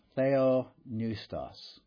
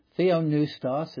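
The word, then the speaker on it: Theopneustos (θεόπνευστος): Pneustos, sometimes the P is silent in Greek. It depends on where you learned Greek and who the teacher was. We're not sure exactly the pronunciation of every word, but Theopneustos is a good way of rendering the Greek there.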